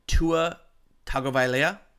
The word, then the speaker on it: Tagovailoa: Their quarterback, Tua Tagovailoa. – Well done. – Tagovailoa.